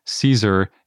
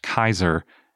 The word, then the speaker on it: Caesar: So Caesar is typically thought to have been pronounced Kaiser historically. So that's just a weird thing that most people don't know. Linguists usually say that it was pronounced Julius Kaiser and not Julius Caesar, for example.